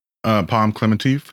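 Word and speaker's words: Pom Klementieff: It's Palm Clemente. Is that how you say her name? – Palm Clemente.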